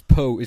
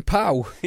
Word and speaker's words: Poe: Which is, someone corrected us on Twitter and said it's Poe, it's pronounced Poe, not Pow. – Oh, get over it. – I know, but I like saying Pow.